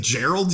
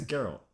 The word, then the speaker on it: Geralt: I thought it was a soft G. – Geralt. Nah, you would be a fool. – Yeah, Gerald Way, yeah. – It's Geralt.